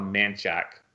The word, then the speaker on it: Menchaca: Like Menchaca, the town of Menchaca and the road Menchaca is called Manchac, which makes no sense.